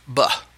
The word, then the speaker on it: ba: one aspect of which is called, pronounced ba,